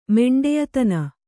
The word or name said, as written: ಮೆಂಡೆಯತನ